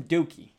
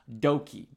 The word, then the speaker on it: Douki: I've been calling him Dookie. Everybody's been calling. All the foreigners have been calling him Dookie. But it's actually spelled and pronounced Doki. D-O-U-K-I.